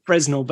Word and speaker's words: Fresnel: It looks like is it Fresnel? – Fresnel. – Fresnel, but it's a silent S.